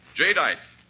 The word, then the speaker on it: jadeite: Remember the name, jadeite. That's spelled J-A-D-E-I-T-E. Jadeite.